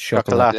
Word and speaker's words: Chocolat: It's chocolate, yeah.